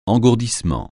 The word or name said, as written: engourdissement